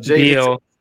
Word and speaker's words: Biel: I don't know. B-I-E-L. – Beal. – Beal, okay, I said it right then.